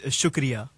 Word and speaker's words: shukriya: So thank you is shukriya.